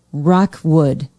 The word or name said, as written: rockwood